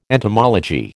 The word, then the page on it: entomology: American English